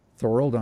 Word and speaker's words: Thorold: But Eric Taylor from uh, Thorold, Thorold, Ontario. – Okay, yeah. – If I'm saying that right. Uh, yep.